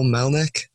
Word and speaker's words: Melnyk: And I have a guest today, Miss Nicole Melnyk.